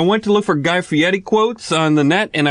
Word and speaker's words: Fieri: Fieri is supposed to be pronounced Fieri like you're doing, but I still think it's an elaborate cover because I don't think Guy can roll his R's like Italians can.